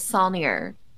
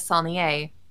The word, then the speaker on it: Solnier: The Texas pronunciation is Solnier. The proper pronunciation is Solnier, as I've been, you know, for long enough. And I think I'm the only one that says it that way in my family, because I got yelled at by a music instructor in college. who told me I was saying my last name wrong.